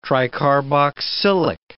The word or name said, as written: tricarboxylic